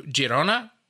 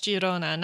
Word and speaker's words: Girona: And it is pronounced Girona, not Girona, because it is Catalan, not Spanish.